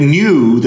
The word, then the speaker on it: nu: and they had to skip nu, the ni, the Greek uh, n, because, um, but because it sounds in English like it would be pronounced like the new variant, it would confuse people.